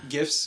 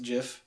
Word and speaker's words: GIF: GIF or JIF? You know the creator of GIFs has come out and called it... it's pronounced JIF.